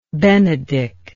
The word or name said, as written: Benedick